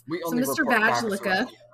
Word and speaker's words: Vaglica: Or it's pronounced Vajlicka. It's spelled V-A-G-L-I-C-A. – Is this a fucking BuzzFeed article you're reading? – No, it's not.